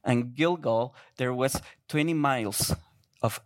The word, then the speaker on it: Gilgal: And I here on the right side, you will see in the right circle, there is, I'm going to try to say this correctly, Gilgal. Is that right? Good. Gilgal, that was the headquarters where the Israelites were.